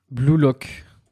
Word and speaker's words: Blue Lock: Blue Lock. Ouais, d'accord. – Alors, il y a Blue Lock, euh, bah, comme ça se prononce en anglais.